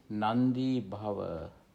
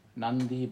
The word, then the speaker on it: nandi bhava: That's N-A-N-D-I. It's pronounced longer. Nandi. In fact, it's one word, but however, I just written it as two different words. Nandi, and then B-B-H-A-V-A, Bhava. Nandi Bhava. This is a compound, actually. Compound. Grammatically speaking, this is a compound. Nandi Bhava. N-A-N-D-I, pronounced longer. So, Nandi, with the diacritic mark. So, Nandi, and B-H-A-V-A, Bhava.